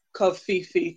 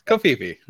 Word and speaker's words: Covfefe: My last point is, I also pronounce it Covfefe too. – Yeah, I kind of like that. It's a made up word. Covfefe.